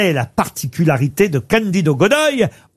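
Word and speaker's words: Godói: Alors peut-être que je prononce mal Godoy, on doit dire Candido Godoy. – Godoy. – Ah, vous connaissez? – Non, mais c'est pas Godoy, c'est Godoy. Déjà, on lit, il faut savoir lire. – Ça s'écrit G-O-D-O-I avec un petit accent sur le O.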